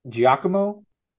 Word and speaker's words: Giacomo: It's Giacomo.